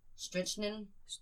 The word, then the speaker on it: strychnine: In fact, she died of, I can't pronounce that, strychnine overdose? – Strychnine. – Yeah, strychnine overdose, which doesn't rule out the possibility of an accident.